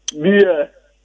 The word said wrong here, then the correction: bear: And then the animal is pronounced bear. Bear.